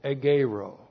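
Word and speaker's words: egeirō: E-G-E-I-R, long O. Egero.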